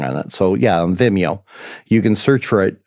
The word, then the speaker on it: Vimeo: How do you say that site? Is Vimeo. it is Vimeo. That's how I say it.